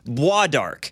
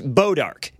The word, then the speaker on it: Bois d'Arc: It's spelled B-O-I-S-D. Apostrophe A-R-C. It's a French word. You would assume bois d'arc. – Yeah. – No. So then I started pronouncing it boudarc. No, it's pronounced bodarc. B-O-I-S is somehow pronounced bodarc.